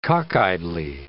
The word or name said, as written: cockeyedly